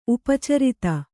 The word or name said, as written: ಉಪಚರಿತ